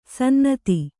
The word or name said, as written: ಸನ್ನತ